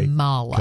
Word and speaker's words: Kamala: She would tell you it's Kamala, it's not Kamala. It's okay. – Kamala.